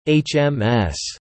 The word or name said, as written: HMS